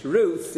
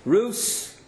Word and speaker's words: Ruth: But it's interesting that the pronunciation in English, Ruth, is more correct than the way we pronounce in Ashkenazi Hebrew or in Sephardi Hebrew, Rut, Rus. I nevertheless do it the Ashkenazi way. But nevertheless, it's not the correct way. The TH is far more correct in the way of pronouncing a thof.